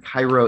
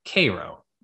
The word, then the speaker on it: Cairo: Next up is a letter from Jonathan Jacobs from Cairo, Illinois. – I believe it's actually pronounced Cairo.